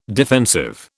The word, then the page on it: defensive: British English